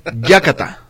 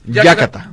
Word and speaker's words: yácata: Muy bien, la palabra del día, compañeros, es yácata. – Yácata. – Yácata. – No, yácata. ¿A qué le suena que es yácata? – Yácata. – Pero así es con acento en la primera, yácata.